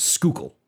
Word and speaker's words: Schuylkill: No, Josh, you don't pronounce the H. It is just Schuylkill. – Schuylkill? – No, Schuylkill. – Josh, you said the H again. – Schuylkill? – Yeah, there you go.